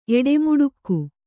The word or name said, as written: ಎಡೆಮುಡುಕ್ಕು